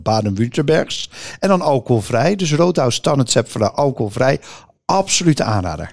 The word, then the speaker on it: Rothaus: Nee, Roodhuis, hè, dus huis op zijn Duits.